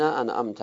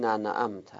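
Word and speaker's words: أنعمت: و در و نیز در سوره شریفه حمد میگوییم صراط الذین انعمت علیهم. بعضیا قرائتشون ممکنه درست نباشه، بخونن صراط الذین ننعمت، ننعمت غلطه.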